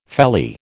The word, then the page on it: felly: American English